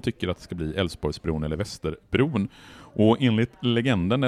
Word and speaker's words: Älvsborgsbron: Och den här lille pojken säger Älvsborgsbron och inser, och då inser Torsten Henriksson att det göteborgarna kommer göra, det är att de hoppar över de här besvärliga konsonanterna i uttalet av Älvsborgsbron. Så det blir Älvsborgsbron som man beslutar att den här bron ska heta 1961.